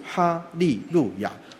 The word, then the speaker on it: 哈利路亚: 所以我小时候也是在教会学哈，哈利路亚赞美主耶稣，哈利路亚赞美主耶稣，祷告就这样。那么这段话什么意思呢？这段话两种语言同样的意思。哈利路亚是古代希伯来人的语言，它的意思是你们要赞美神，所以它的发音就是哈利路亚。